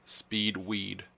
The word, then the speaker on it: Speed Weed: S-P-E-E-D. – Mm-hmm. – That's the first name. Second, the last name is W-E-E-D. It's exactly what it sounds like, man.